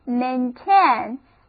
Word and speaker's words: maintain: 当我们说到保持什么状态的时候，我们用单词 maintain。今天我们就首先来学习一下这个单词 maintain M-A-I-N-T-A-I-N，两个字母组合 A-I 都发 A 的音，这样不仅字母拼写对称，而且发音也对称的单词很少见，我们记起来很省力。好的，重音放在第二个音节上， read after me，这是 maintain，保持、维修、主张的意思。